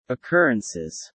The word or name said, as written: occurrences